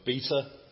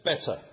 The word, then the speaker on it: beta: Some people look at the word beta and, not knowing how it should be pronounced, pronounce it better.